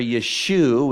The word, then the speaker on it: Yeshu: The Talmud says, on the eve of Passover, Yeshu, which is the Hebrew pronunciation of Jesus' name, was hanged, speaking about the fact that he was hung on the tree or the cross.